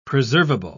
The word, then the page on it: preservable: British English